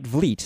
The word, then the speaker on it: Vleet: And just to clarify, my name is pronounced Vleet, like fleet. – All right, Vleet.